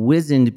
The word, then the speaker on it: wizened: So I've been saying wizened because I heard it on a British audiobook. – Oh. – They take whizzes. – Right. So it's possibly one of those things that has a different pronunciation across the pond.